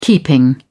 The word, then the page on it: keeping: British English